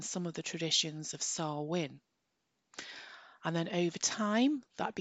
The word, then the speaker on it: Samhain: came from the Celtic festival of Samhain. So I'll spell that for you because it's spelt completely different to the way that it's pronounced. And it's S A M H A I N.